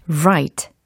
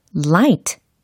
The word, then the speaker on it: right: 혹시 light 라고 하고 있진 않는지 한번 살펴보세요. light, light 이 발음과 오늘 연습할 문장 속에 right 이 발음의 차이가 있습니다. 그쵸? right냐, light냐. 다시 한번 들어보세요. right, light, right, light. 차이가 분명히 있죠.